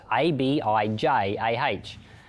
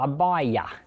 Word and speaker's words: Abijah: Now, that's spelt A B I J A H. Some people say Abijah, which I do sometimes too, or Abijah or Abaya, because the J can be a Y sound.